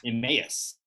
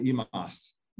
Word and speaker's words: Emmaus: Emmaus. – Emmaus. Okay. Back in the day, they called it Emas, but it's called it's known as Emmaus.